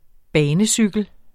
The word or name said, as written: banecykel